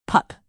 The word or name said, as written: pup